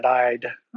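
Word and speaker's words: Eide: Well, you know, I'm pilfering everything I'm about to say from the work of Brock and Fernet Ide, or ID, depending on how you want to pronounce it, their book called The Dyslexic Advantage.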